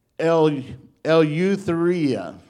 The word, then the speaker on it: Eleutheria: And the word liberty right there in the Greek is pronounced, here we go, because it's like a big one, about 12 letters here. Eleutheria, okay, which means freedom.